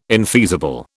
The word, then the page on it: infeasible: British English